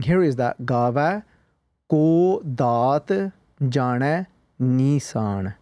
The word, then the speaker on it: Gaavai: And again, trying to keep focused on the actual pronunciation as well. There are many people that when we sometimes read this bani, read Guru Nanak Dev Ji's Japaji in a rush, it can turn into Gave, which is then a lav.